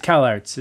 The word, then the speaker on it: Kallax: It's Kalarts is actually how it's pronounced.